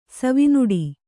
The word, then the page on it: ಸವಿನುಡಿ: savi nuḍi